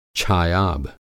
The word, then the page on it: छायाभ: Hindi